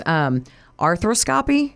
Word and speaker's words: arthroscopy: And I know there's arthroscopy.